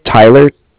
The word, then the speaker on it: Tyler: Okay, well, my name is Tyler, T-Y-L-E-R. – Okay. – Like I said, I'm calling with Cardiff. It's spelled C-A-R-D-I-F-F, as in Frank, Frank.